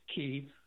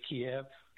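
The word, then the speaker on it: Kyiv: Tomorrow when you talk to Philip Idner, please, don't say Kiev, say Kiev. – Kiev is the Russian pronunciation. Kiev is the Ukrainian pronunciation.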